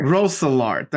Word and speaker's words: Roeselare: Roeselare.